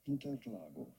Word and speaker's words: Interlagos: It is pronounced something along the lines of Interlagos.